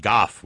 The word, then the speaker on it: golf: Yeah, just like they play it in Scotland, they pronounce it goff without the L.